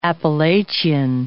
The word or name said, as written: Appalachian Mountains